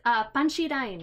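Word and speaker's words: panchirain (パンチライン): Okay, so when you pronounce punchline in Japanese, you pronounce it and it goes, it sounds panchirain, right?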